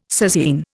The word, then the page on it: sasine: Indian